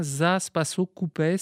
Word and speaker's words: Zaspaso Koupes: Il s'agit d'une chanson qui emprunte donc ses mélodies à la Grèce, au Kosovo, ça s'appelle, je ne sais pas si je le prononce bien, Zaspaso Koupes, donc c'est une chanson grecque. – Zaspaso Koupes, parfait.